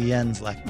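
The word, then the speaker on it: Jens Lekman: Well, and by the way, if he's Swedish, have I even got his name right? – It's actually Jens Lechman.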